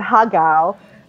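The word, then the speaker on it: har gow: We're gonna do Chinese food, and when it came to H, like my husband who's Cantonese, he said ha gao, and which is it's not an H word, but that's how you say it in Cantonese, and I guess the phoneticization would be H.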